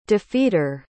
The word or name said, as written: defeater